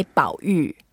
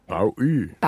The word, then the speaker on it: 保育: Let's learn how to say it in Chinese. We say 保育. – 保育. 保育. That means conservation. 保育. – Yes, 保育, that means conservation. 保, that means to protect, and 育 means to nurture.